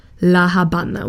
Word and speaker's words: La Habana: In der Landessprache wird Havanna La Habana ausgesprochen.